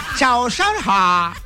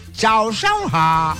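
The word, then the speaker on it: Zao shang hao (早上好): Zao shang hao. Hay que colocarle así el tono, ¿no? El acento. Chao, chao, ha.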